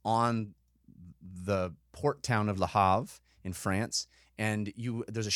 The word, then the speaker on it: Le Havre: You don't pronounce the R? – It's French, so you can... – It's there. You can hear it. – You go Le Havre. Le Havre. But I don't do that very well.